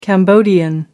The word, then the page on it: cambodian: American English